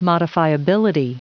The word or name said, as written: modifiability